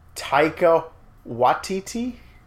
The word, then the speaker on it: Taika Waititi: Taika Waititi? – Okay.